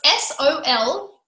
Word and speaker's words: solfeggio: So it's SOLFEGGIO, solfeggio.